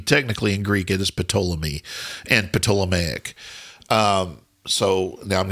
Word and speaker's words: Ptolemy: In Greek, it would be Ptolemy. It would you pronounce the P. – Every consonant is pronounced in Greek.